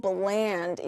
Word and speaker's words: bland: 그거는 blend, 믹싱하다 뭐 이런 뜻이지만 섞다 이런 뜻이지만 이거는 blend가 아니라 bland 이렇게 발음을 합니다.